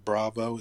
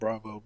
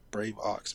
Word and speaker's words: Braveaux: And then Bravo is pronounced Bravo, but it's spelled like Brave. And then AUX, like an aux chord. That's one word, so Brave Ox, but it's Bravo. Please don't call me Brave Ox.